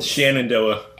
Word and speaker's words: Shenandoah: Spencer and I were kind of debating about how you pronounce Shenandoah, and I pronounce it very much as Shen and Shenandoah. – And Spencer says Shan, so maybe they should just change the letter and made it S-H-A-N instead of S-H-E-N.